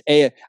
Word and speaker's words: Ai: It's pronounced Ai. I was corrected, because for years I'd called it AI, but I was told again and again, it's Ai. Just say like iPhone, iPad. So just for future reference, you know, if you want to, it's AI.